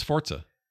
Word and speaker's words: Forza: It's Forza. That's how you say it. – You say it like with a T. – Yeah, you're supposed to. – Is it not Forza? – It's like Italian or something.